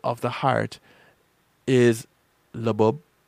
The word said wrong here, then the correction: lub dub: labub, labub. That's what I said. It's lub dub, lub dub. I said it wrong.